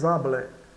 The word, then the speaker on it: habiles: Aqui é uma ligação, né? O S com H muda aqui, o H é mudo. Então, Charlie et Henri sont très habiles.